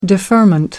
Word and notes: deferment: British English

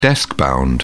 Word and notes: deskbound: British English

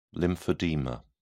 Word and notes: lymphedema: British English

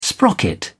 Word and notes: sprocket: British English

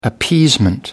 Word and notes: appeasement: British English

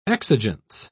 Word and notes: exigence: British English

